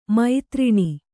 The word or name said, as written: ಮುದ್ರಣ